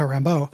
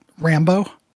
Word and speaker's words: Rambeau: I will say that everybody in the show pronounces Monica Rambeau and puts the emphasis on Rambo. So it just sounds like John Rambo. You know, I feel like they're not putting enough spin on it to make it seem, you know, adequately pronounced.